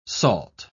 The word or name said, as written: salt